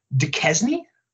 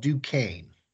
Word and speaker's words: Duquesne: And the last one, coming from the smallest program so far, correct me if I say this wrong, but I'm saying it the way it's spelled. Duquesne? – No, it's actually called Duquesne.